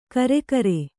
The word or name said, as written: ಕರಿಕಾರೆ